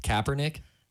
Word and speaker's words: Kaepernick: Yes, we do know it is actually pronounced Kaepernick.